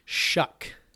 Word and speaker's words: Shough: How do you say his name again? – Shuck. – Tyler Shuck. Shuck. – S-H-O-U-G-H. – So, Shuck. No more Justin Herbert, obviously.